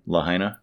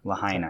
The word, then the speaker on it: Lahaina: Lahaina. – Lahaina.